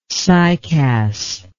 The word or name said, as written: cycas